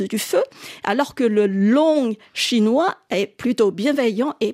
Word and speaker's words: loong: La bonne traduction, selon eux, aurait dû être « long », L-O-O-N-G, qui est proche de sa prononciation en chinois.